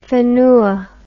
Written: whenua

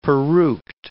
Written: peruked

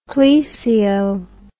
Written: plesio

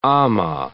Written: amah

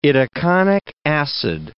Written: itaconic acid

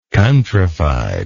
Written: countryfied

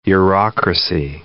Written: Eurocracy

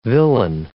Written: villein

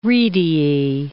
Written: rediae

